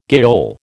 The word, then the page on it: gaol: American English